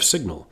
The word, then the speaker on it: sign: And we noticed that the pronunciation of that base, S-I-G-N, is changing. In design, we say zine. In signing, we say sign. And in signal, we say something like sign or something. And it's by looking at those words that we can understand why there's a G in the word sign, because we need it for some of the pronunciations.